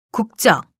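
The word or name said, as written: 그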